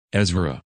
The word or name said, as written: Ezra